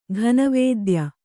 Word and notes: ಘನವೇದ್ಯ: kannada

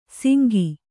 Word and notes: ಸಿಂಗಿ: singi

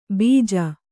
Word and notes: ಬಿಜ: bija

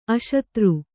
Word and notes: ಅಶತ್ರು: aśatru

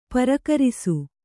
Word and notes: ಪರಕರಿಸು: parakarisu

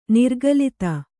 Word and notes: ನಿರಾಕುಲತೆ: nirākulate

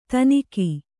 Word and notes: ಥಣ್ಣಗೆ: Kannada

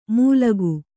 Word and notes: ಮುಲ್ಗು: mulgu

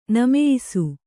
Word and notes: ನಮೆಯಿಸು: Kannada; nameyisu

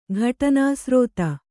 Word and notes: ಘಟನಾಸ್ರೋತ: Kannada

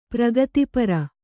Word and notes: ಪ್ರಗತಿಪರೆ: pragati pare

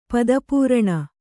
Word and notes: ಪಾದಪೂರಣ: kannada

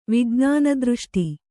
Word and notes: ವಿಜ್ಞಾನದೃಷ್ಟಿ: Kannada

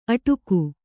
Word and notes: ಅಟ್ಟಗೆ: Kannada

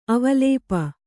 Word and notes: ಅವಲೇಪ: avalēpa

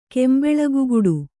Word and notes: ಕೆಂಬೆಳಗುಗುಡು: Kannada

